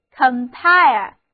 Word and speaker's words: compile: 现在我们在 pile 前面加上一个表示在一起的 con 前缀，那么 compile 重音放在第二个音节上， compile 就读作 compile，就是把不同的资料都放在一起，所以 compile 就是编辑、编撰。